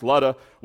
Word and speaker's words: Lydda: And for as much as, and I believe it's pronounced Lutta, I like Lydda better but sometimes when we translate things to English, words get really messed up. But I believe it's pronounced Ludda.